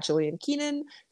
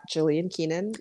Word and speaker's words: Jillian Keenan: My username is at Jillian Keenan. Same for Instagram. I think it's just at Jillian Keenan. My name is spelled J-I-L-L-I-A-N-K-E-E-N-A-N.